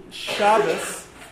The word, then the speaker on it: Shabbos: Okay, the variation between Shabbos and Shabbat is really a difference in pronunciation of Hebrew between Sephardic and Ashkenazic Jews.